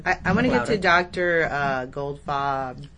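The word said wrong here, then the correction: Goldfaden: Gofadden. – Right. How do you spell that? – G-O-L-D. Goldfadden. F-A-D-E-N.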